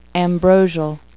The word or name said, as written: ambrosial